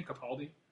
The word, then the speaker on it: Capaldi: Is that how you pronounce his name, Capaldi? – I believe so, yeah.